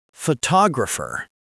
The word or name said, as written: photographer